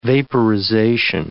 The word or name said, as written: vaporization